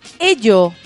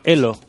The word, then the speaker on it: Ello: Les conté hace un rato que hay una nueva red social que se llama Ello. Qué cosa más rara. ¿ah? – Creo que se llama Elo. – Elo, pero se escribe Ello.